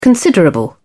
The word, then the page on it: considerable: British English